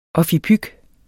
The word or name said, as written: OFFIPYK